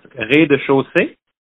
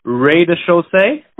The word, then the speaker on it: rez-de-chaussée: Oh, so you could say it like, in French, it's rez-de-chaussée. You could say rez-de-chaussée, maybe.